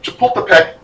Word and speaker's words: Chapultepec: And they did really well, especially at the Battle of Chapultepec. No, I said it right the first time.